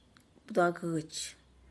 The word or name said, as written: bduagutx